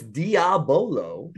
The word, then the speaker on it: Diabolo: Is that, have you done Diablo for a while? – Oh, yeah. – What's Diablo? – So it's Diabolo. – Yeah, I was saying it wrong.